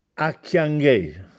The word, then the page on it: Acchianghè: Castellanetano